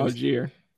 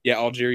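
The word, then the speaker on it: Allgeier: I don't know how to pronounce their other running back's last name, but I know Algier. – Algier.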